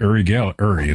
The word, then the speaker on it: Uri: In fact, Uri is how you really pronounce it.